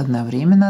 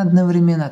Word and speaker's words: одновременно: С ударением та же ситуация, одновременно, одновременно, творог, творог. Поэтому я бы сказала, что расслабьтесь и говорите, как хотите, шайбер, шабер.